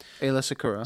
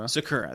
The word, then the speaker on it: Secura: Secura. – Aayla Secura. – Secura, that's it.